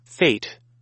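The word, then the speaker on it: fête: What is this accent over the E? How you pronounce this? – Fate. – Fate. Oh, I guess I had it right.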